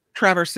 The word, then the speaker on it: Traverse: A-R-B-U-T-U-S. Arbutus. Arbutus Lake. – Was it Traverse City? Is that how you say it? Traverse? – Traverse City, yeah.